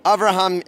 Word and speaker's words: Avraham: Avraham Yemini.